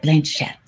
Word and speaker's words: Blanchett: Did I say that right? Blanchett. – Yeah.